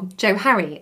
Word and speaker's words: Johari: So they just squished the first parts of their first names together, Joseph and Harrington, to make the name Johari, or Johari, I think some people pronounce it as.